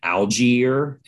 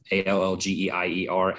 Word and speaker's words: Allgeier: Allgeier, A L L G E I E R, Allgeier.